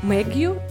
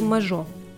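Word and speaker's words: Mageau: Майкл Мэгью или э, Майкл Мажо. Имя его переводят по-разному, а, но мне больше нравится вариант Мажо. Мне кажется, что фамилия читается именно так, поэтому я буду называть его Мажо в дальнейшем.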